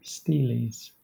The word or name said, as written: steles